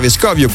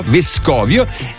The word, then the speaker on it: Vescovio: Io, se ho sbagliato, mi corrigerete, preferisco sempre comunque andare a verificare, e infatti la Treccani ci dice che la tendenza a spostare l'accento sulla O, Vescovio, nasce da una lettura spontanea ma scorretta della desinenza in io. Sebbene la corretta accentazione sia Vescovio,